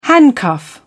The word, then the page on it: handcuff: British English